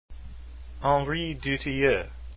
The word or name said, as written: Dutilleux